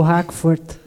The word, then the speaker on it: Hakvoort: Eu acertei a, a pronúncia? – Acertou, Hackford.